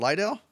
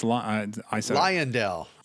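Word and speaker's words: Lyondell: How do you pronounce it? Lydell? – No, it's Liondell. Yeah, the VP Racing Liondell.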